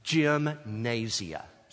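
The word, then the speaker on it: gymnasia (γυμνασία): The word discipline in those verses where he says, discipline yourself for the purpose of godliness, is pronounced in the Greek language, gymnasia. Sounds like a familiar word, doesn't it? Because it's the very word we get for gymnasium.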